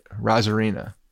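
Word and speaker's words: Arozarena: I want you to get rid of the A in front of his name and just say it, Rosarena, because that's how you say it. Randy Rosarena. Forget about the A in front. Don't even pronounce it.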